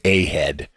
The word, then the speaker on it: A-hed: But one of the things that has always been true of the Wall Street Journal is something known as the A-head, and it's spelled A-H-E-D, not H-E-A-D.